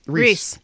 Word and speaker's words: Reese: Robert Reed. – Reese. – Can you spell that? – R-E-E-S-E. – Like Reese's Pieces? Okay.